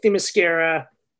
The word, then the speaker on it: Themyscira: And this opening that we're talking about, might as well launch into it, is that we begin on Themyscira. Wait, I said that right. Sounded wrong when I said it.